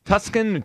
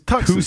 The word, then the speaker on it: Tucson: I heard some pronunciation issues yesterday with Tuscan. Tuscan? – He was trying to give us a lesson on pronunciation. Yeah. It was just wrong. – No, I know it's Tucson.